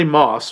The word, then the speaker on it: Motss: Dustin Hoffman, who plays Stanley Moss, by the way, Stanley Moss's name is spelled M-O-T-S-S, but the T is silent.